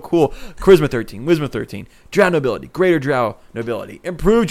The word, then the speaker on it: Drow: Drow rhymes with wow.